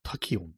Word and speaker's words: tachyon: あと、タキオンって読むんですよね。